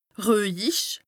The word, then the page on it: ruehjich: Bas Rhin; Strasbourg